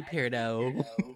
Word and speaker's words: peridot: It's actually Pirdo. – I told you to go off, Hermione. – The T is silent in that.